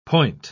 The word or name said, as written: point